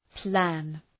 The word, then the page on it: plan: plæn